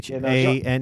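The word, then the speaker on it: Channard: Is it pronounced Chinard? – No, it's Chinard. – Really? – Yeah, Chinnard.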